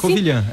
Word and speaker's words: Covilhã: Estamos ouvindo aqui de Covilhã, é assim? – Covilhã, é isso mesmo.